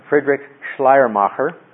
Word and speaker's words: Friedrich Schleiermacher: One of the things I bring up, this fellow, and this is the fellow last time I think I butchered his name. I'll try again. Friedrich Schleiermacher. Is that a little better? Not too bad.